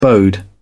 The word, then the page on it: bode: British English